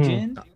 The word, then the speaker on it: Gin: And this arc, you know, Baratie, it actually kicks off with Gin, right? Is that how you say his name? Gin? Mm-hmm. Jin? – Yeah. – Okay. Jin, I always say Jin.